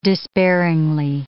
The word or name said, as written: despairingly